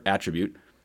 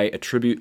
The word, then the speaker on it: attribute: And then in English, we have the verb attribute. Notice the emphasis on the second syllable. Like, I attribute my success on the court to my uncle who taught me how to play tennis.